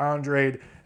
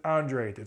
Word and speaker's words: Andrade: Fox has fought absolutely nobody This is a showcase fight for Andrade. And I realized I just said Andrade a second ago. Sorry, that's just how the name looks to me. But they always pronounce it Andrade.